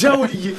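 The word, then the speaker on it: joaillier: Jolier oui, je La prononciation est pas parfaite, mais, mais euh, ça passe. – Jaoulier. Jaoulier, très bien.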